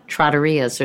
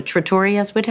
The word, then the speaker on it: trattoria: And so why that sudden turn back into, or yeah, I guess to your roots of loving trattorias or trattorias? How do you say that? – Trattoria. – Trattoria. – You got it.